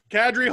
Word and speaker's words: Kadri: Kadri, Hall, and who cares? That's how you pronounce it, by the way. I asked. Because I asked him.